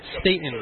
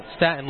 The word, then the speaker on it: Staten: So our mistake, it is not Staten like Staten Island. That's how it's spelled. – Right. – Apparently when you get down below the Mason-Dixon line, we change that.